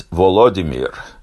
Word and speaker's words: Volodymyr: So in American English, that's Vladimir. In Russian, it's Vladimir. But in Ukrainian, it's Volodymyr. And each language pronounces it somewhat differently.